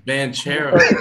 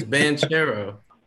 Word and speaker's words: Banchero: It's Banchero. – Go ahead, Big Perk. – It's Banchero. – He try to have me sound like Perk. – Paolo Banchero. – Paolo Banchero.